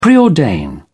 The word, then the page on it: preordain: British English